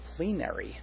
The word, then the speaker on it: plenary: It's plenary. Yeah, with the long E, that's right.